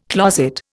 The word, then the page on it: closet: Indian